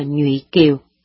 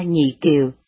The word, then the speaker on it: Nhụy Kiều: Nhưng vì người dân miền Tây thường phát âm sai cho nên chữ Nhụy Kiều thành ra Nhị Kiều cho đến bây giờ.